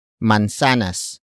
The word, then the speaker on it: mansanas: And that is mansanas. Mansanas. Mansanas. Mansanas is spelled as M-A-N-S-A-N-A-S.